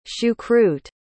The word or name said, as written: choucroute